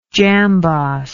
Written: jambos

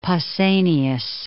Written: Pausanias

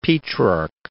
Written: Petrarch

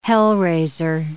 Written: hell-raiser

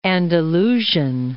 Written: Andalusian

